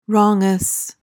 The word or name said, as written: wrongous